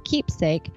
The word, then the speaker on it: Qeepsake: Sign up for Qeepsake for free at qeepsake dot co forward slash peace, pronounced keepsake. Note it's co, not com. That's qeepsake. Q E E P S A K E, like keepsake, but with a Q.